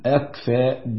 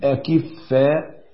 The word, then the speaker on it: أكفاء: والصواب عشان نبين كلمة أكفاء، الصواب في نطقها أن احنا نقول أكفاء بسكون الكاف وفتح الفاء، وما نقولش، لأن أكفاء جمع كفء، والخطأ أن احنا نقول أكفاء بكسر الكاف وتجديد الفاء مع فتحه، لأن أكفاء جمع كفيف،